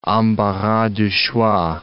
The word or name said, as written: embarras du choix